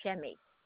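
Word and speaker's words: Chemij: It's C like in cat, H-E-M-I-J like in Jack, pronounced Shemmy.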